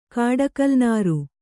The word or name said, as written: ಕಾಡಕಲ್ನಾರು